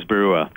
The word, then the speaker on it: Barua: I'm sorry, how do you say your last name, sir? – That's Bacchus Barua.